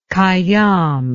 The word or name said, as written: Khayyam